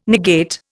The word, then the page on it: negate: Indian